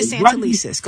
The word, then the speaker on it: Santelices: But we're not going to disrespect her and mispronounce her name. It's Dr. Sonia Santelices.